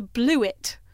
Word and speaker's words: Blewit: There's a new device on the market called a Blewit. – How do you spell that? – B-L-E-W-I-T. Okay. As in, shit, I blew it.